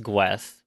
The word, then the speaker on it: Gwes: So, it's actually Guess in like the manga and stuff? – The katakana... – No, it is pronounced Gwes. – Yeah, it is written as Gwes. – Half a letter away from Guess.